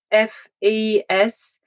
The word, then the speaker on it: Fasnacht: So Geraldine Fasnacht, it's Fasnacht.